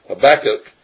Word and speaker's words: Habakkuk: Now it says of Habakkuk, or Habakkuk, I've been pronouncing it incorrectly, Habakkuk, nothing can be really known beyond what he says of himself.